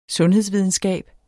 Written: sundhedsvidenskab

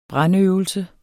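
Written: BRANDØVELSER

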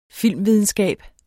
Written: filmvidenskab

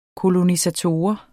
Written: kolonisator

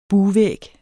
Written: bugvæg

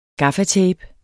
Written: gaffatape

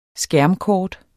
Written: skærmkort

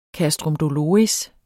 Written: castrum doloris

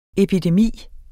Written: epidemi

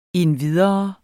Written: endvidere

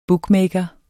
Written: bookmaker

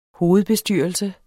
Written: hovedbestyrelse

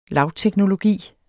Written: lavteknologi

